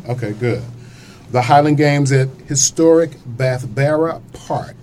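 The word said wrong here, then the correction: Bethabara: Did I say that name correctly? – Not quite. – Okay. So that's how I say it when I'm trying to spell it. – I'm from the Midwest. I just said it. – Bathabra. – Bathabra. Okay.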